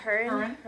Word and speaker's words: Huron: The Night We Met by Lord Huron. Is that how you say it? – Yeah, Huron.